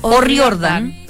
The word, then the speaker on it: O'Riordan: O'Riordan. – Tal cual. Claro, porque por su origen eh, escocés se tiene que pronunciar tal cual. O'Riordan.